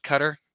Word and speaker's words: Qatar: But come 2026, uh, yeah, it's pronounced Cutter, Q A T A R.